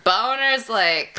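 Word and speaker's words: Bohner's Lake: All right, it's spelled B-O-H-N-E-R. – Boner's Lake. – People are like, "Oh, Bonner," and I'm Bonner. – Nope.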